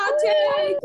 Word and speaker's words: Tatte: What is the proper pronunciation of this word? T-A-T-T-E. Is it tate? Is it tat? Is it tate? Or is it tatty? – My favorite question so far. – Tate! Good job, everyone.